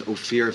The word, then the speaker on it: Ofir: My name is Ophir Ventura. It's spelled O-F-I-R for the record.